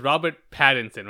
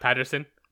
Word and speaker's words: Pattinson: And Robert, I always get his name is Robert Pattinson, right? Not Patterson. Yeah, I always get his last name wrong.